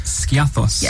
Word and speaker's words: Skiathos: Elena, you're from the sunny Greek island of Skiathos. – Yes. – Is that the right pronunciation? – That is the correct pronunciation.